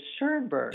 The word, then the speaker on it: Schoenberg: Is it Schoenberg or Schoenberg? – Actually, it is Schoenberg. – Schoenberg. – That is the correct fancy pronunciation.